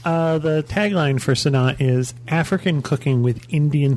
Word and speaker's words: Sanaa: Am I saying that right, Sanaa? Mm-hmm.